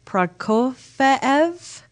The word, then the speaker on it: Prokofiev: You'll hear classical pieces like, okay, I had to look up how to pronounce him. So it's Prokofiev, Romeo and Juliet, and iconic film scores, including Apollo 13, which is my favorite composer, James Horner.